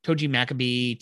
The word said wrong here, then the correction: Togi Makabe: Yeah, so Togi Makabe, that's how you say his name.